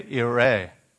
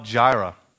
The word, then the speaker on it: Yireh: Genesis 22:14, Abraham called the name of that place, the Lord will provide, Yahweh Yireh. Or we would say Jehovah Jireh with our English J pronunciation.